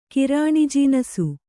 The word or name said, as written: ಕಿರಾಣಿಜೀನಸು